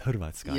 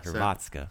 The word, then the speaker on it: Hrvatska: So, uh, accents on the second syllable. So now you guys can see Vienchanya is on the second syllable. You guys can do your your your uh, cra- cra- crybaby uh, thing when I correct you when it's Hrvatska, yeah, not Hrvatska.